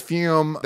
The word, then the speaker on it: Fume: All right, real quick, we must thank one of our incredible sponsors, which is Fume. That is spelled F-U-M, but it's pronounced Fume.